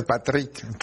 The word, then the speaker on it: Patrick: No es inglés, es francés, Patrick.